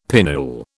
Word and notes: pinnule: British English

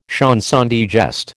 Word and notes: chanson de geste: American English

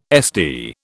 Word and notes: SD: British English

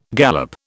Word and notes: gallop: British English

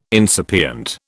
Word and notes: incipient: British English

